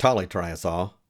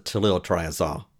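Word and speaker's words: tolyltriazole: Now, I know some people pronounce it tolytriazole, but there is a second L in there. I was taught to pronounce it toluol triazole.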